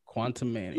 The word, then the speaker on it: Quantumania: Uh how you pronounce that? Quantumania? – Quantum Mania. – Look at you.